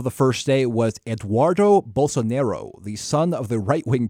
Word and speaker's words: Bolsonaro: Bolsonaro, if I say it right, Bolsonaro.